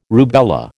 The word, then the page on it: rubella: American English